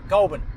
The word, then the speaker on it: Goulburn: You don't Goulburn. Say the burn. No, it's Goulburn. – Goulburn. – So it's Goulburn. It's not Bond, but it's like halfway between.